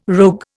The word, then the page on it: rogue: Indian